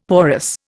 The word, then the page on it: porous: Indian